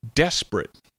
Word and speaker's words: desperate: Now, desperate is technically three syllables, but the second one is barely audible, so it sounds more like two syllables. Desperate.